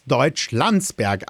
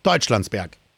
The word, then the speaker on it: Deutschlandsberg: Und deshalb spricht man heute das als Deutschlandsberg aus und nicht Deutschlandsberg.